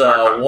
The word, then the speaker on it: Archon: It's called Archon. It's Archon. – A-R-C-O-N? – Archon. Yeah. A-R-C-H-O-N.